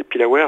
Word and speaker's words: pilawer: Comment est-ce que ça se prononce? C'est les pilawer? – Les pilawer, ouais, en breton, ouais.